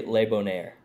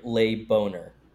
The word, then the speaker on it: Le Bonheur: No, you say Le Bonheur. – Le Bonheur. – You say it like Le Boner. – Le Bonheur. Le Boner.